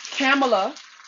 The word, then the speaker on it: Kamala: First of all, Joe Biden announced that Kamala, because that's how she pronounced it, is his VP running mate.